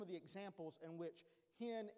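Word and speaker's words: hen (חן): But it's hen, and it looks like C-H-E-N, but it's pronounced hen. And then hesed, and it's also wrote C-H-E-S-E-D.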